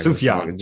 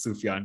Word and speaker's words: Sufjan: and then like Sufjan Stevens Chicago. – Sufjan, just a soft J. – Is it? It's like just so I'm joking, but like Yaz flute, but like it actually is Sufjan, yeah.